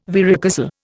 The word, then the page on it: varicocele: Indian